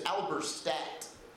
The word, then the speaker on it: Alberstadt: Hey, in case you missed it, folks, my last name is Alberstadt, which in telemarketer language is pronounced Mr. Abercrat. Mr. All-Star Back. My personal favorite, Mr. Monistat. Good evening, is this Mr. Monistat?